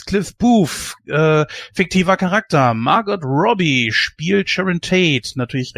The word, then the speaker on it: Margot: Ja, aber ich glaube, sie spricht sich selbst Margot. Ja, dann, dann sagen wir Margot.